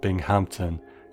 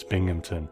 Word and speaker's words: Binghamton: It's not Binghamton, it's Binghamton.